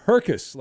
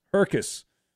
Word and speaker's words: Herkus: He says the name we were trying to pronounce is Herkus, like circus, Herkus.